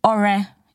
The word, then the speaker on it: Ore: Okay, so Ore is my name. My full name is Ore Oluwa, and there's a dot under the O and a dot under the E to show that it is Ore and not Ore,